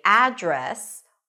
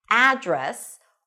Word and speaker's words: address: You can only use one pronunciation, and that is address. Stress is on the second syllable.